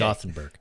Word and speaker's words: Gothenburg: He says, Gothenburg, or Gothenburg, as I speculated that it may be, is the English name for my hometown. It's pronounced how it looks. – Okay. Gothenburg.